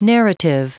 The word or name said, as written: narrative